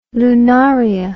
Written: lunaria

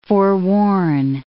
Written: forewarn

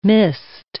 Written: mist

